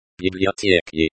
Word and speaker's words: библиотеки: Pronounced библиотеки, stress on the second syllable.